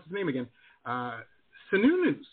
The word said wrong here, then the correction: Cernunnos: or Sununos, right? I'm probably saying that. Cernunnos, yeah. – Cernunnos.